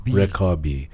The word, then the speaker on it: Rekabi: How do I say her name? – Rekabi. Yes.